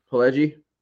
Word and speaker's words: Pileggi: Pileggi.